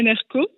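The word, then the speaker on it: NRK: Donc, je suis journaliste freelance à NRCO, avec un K à la fin, mais qu'on prononce CO, et ADN aussi, je fais les commentaires live.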